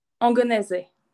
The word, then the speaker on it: Angonese: Angonese.